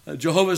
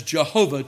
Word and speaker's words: Jehovah: There are those who would say, no, that's not what it is. It should be Jehovah. Jehovah is a fine pronunciation. We need not worry about that.